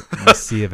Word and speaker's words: Mesilla: I was like, hey, you know, this is how you say it. Mesilla Valley.